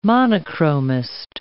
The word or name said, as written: monochromist